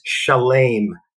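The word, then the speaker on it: shalem (שלם): But then there's another pronunciation of those three self-same Hebrew letters, and the word is shalem, and it means fulfilled, whole, complete.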